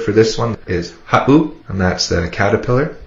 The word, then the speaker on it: ha7u: If you listen to that word ha'u, ha'u, it would be spelt H-A-7-U. If you were to take out that seven, it would be pronounced ha'u. With the seven in there, makes that little glottal stop, that pause.